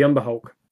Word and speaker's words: Umber Hulk: Oh, the Umber Hulk.